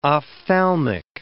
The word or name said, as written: ophthalmic